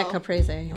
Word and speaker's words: Caprese: Yeah, Caprese.